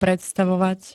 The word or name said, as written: predstavovať